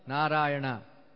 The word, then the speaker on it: Narayana: At the time of death, he chanted once, Narayana, 4 syllables.